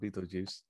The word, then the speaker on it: Betelgeuse: pronounced on occasion spelled Beetlejuice, from the Neverworld, who tries to scare away the new inhabitants.